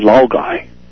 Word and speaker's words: Laogai: Chinese call their system so-called Laogai, L-A-O-G-A-I. And today, Laogai is a word in the Oxford Dictionary since 2003. Lao, L-A-O-G-A-I, is the pronunciation from two Chinese letters.